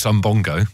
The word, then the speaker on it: Umbongo: I'm getting, I can't say it properly, Umbungo vibes. Someone's texting saying it's not Umbungo, it's Umbongo.